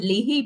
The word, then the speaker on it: Leahy: I would pronounce it Leahy, but I'm Irish.